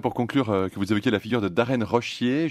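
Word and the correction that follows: Roshier: Oui, c'est Rosier. Comme ça, Rosier, voilà. Rosier, il y a pourtant un H qui se glisse entre le S et le I,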